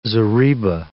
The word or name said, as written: zariba